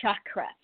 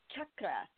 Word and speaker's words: chakra: And the way you say it is with that hard C, like hard CH, like you would say church. You don't say chakra, even though they can be shocking. But you say chakra, actually, with a little bit of a on the R part.